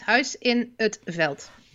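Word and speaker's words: Huis in 't Veld: It's Huis in Utveld.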